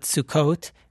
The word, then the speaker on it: Sukkot: We call it Sukkot, and the English translation, it's typically called the Festival of Booths.